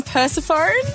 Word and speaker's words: Persephone: Persephone, I believe.